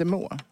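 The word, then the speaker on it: Meaux: Och då ska vi prova den med en brie de meux. Mm. Och det är riktiga opasteuriserade brine. – Är det meux? Är det inte må? – Må uttalas det såklart.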